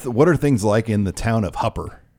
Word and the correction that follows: Hooper: Did I say it right? No. Hooper.